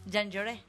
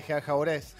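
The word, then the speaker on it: Jean Jaurès: Se dice así, aunque hay gente que le dice Gianjaures. – Gianjaures. – También, ¿por qué no? Pues son muy, muy argentinos, lo dicen en tono francés, menos después de lo que pasó.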